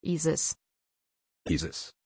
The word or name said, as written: isis